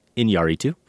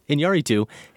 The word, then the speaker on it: Iñárritu: Not Iñárritu, but Iñárritu. The accent is on the A.